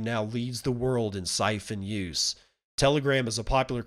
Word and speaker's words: Psiphon: and Canadian proxy service Siphon, and that's spelled P-S-I-P-H-O-N.